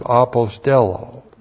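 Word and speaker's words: Apostello (ἀποστέλλω): In 1 John 4 verse 9, the key word here is the verb apostello. Looks like that in the Greek, A-P-O-S-T-E-L-L, long O. Apostello.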